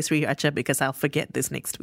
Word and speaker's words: sriracha: Andrew also says, by the way, I think sriracha is pronounced as sriracha. And I just said it the way that we said it at the start during our Thailand-Vietnam kerfuffle. But also because... Yeah, I think you're right. I have seen... So the founder of the company has confirmed this, that it is sriracha. I think that... Even now I'm saying it wrong. I think it's sriracha.